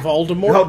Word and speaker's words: Voldemort: Voldemort, for the T is silent because it's French, even though they never say that in the movies, was Ramesses the Great.